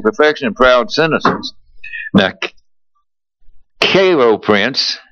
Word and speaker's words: Cairo: Am I pronouncing that right? Do you remember? Cairo Prince. It's either Cairo Prince or Cairo Prince. Cairo. Yes, it's Cairo because it's...